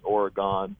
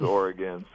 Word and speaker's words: Oregon: I mean, I have some family back east, and they pronounce it out east, Oregon. – Amateurs. – It's like it's Oregon, so I kind of get a little bit mad.